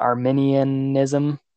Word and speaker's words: Arminianism: I always say like the country, like Arminian, but I think it's like Arminianism or something.